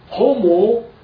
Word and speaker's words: homoousios: But in the English form, it was spelled out, it is written as H-O-M-O-S-U-I-S-O-S. Okay? Now, how is this word pronounced? It's pronounced as homoousios.